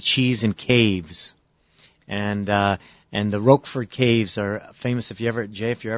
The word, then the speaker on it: Roquefort: The most famous is Roquefort.